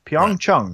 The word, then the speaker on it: Pyeongchang: It's like, what's the proper pronunciation? – Pyeongchang.